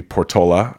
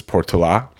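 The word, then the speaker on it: Portolá: So there was a famous explorer called, with the American accent, would be Portola. I think the Spanish pronunciation is Portola.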